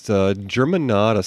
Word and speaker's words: Germanotta: Germanotta.